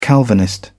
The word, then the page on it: calvinist: British English